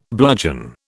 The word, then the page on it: bludgeon: British English